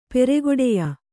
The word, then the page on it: ಪೆರೆಗೊಡೆಯ: peregoḍeya; kannada